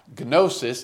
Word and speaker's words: gnosis: Gnosis is G-N-O-S-I-S. Gnosis. And of course, you can see Gnostic. Now, we say Gnostic, we make the G silent, but the Greeks would pronounce it with the G.